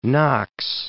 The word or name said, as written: Fort Knox